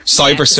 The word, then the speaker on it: Cyberster: Cyberster. – Cyberster.